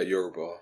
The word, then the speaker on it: Yoruba: Yeah, Yoruba. – Yeah. Yoruba people did come from the Nile region, didn't they? They come from Egypt. – Yeah, it's really interesting, I feel like, because even with the Yoruba, they talk about accounts of them coming down from north into West Africa.